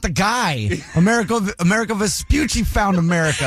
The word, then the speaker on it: Amerigo Vespucci: Amerigo Vespucci.